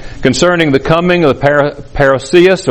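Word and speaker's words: parousia: Notice again, verse 1, "Now concerning the coming," the word there, translated coming, is parousios, or parousios, it's pronounced variously, but parousios.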